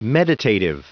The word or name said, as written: meditative